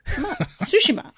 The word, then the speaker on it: Tsushima: Did you settle on Tsushima? – It's Tsushima. – Good. That's what I've been saying. I'm just saying. – It's Tsushima. I just remember sushi. Tsushima.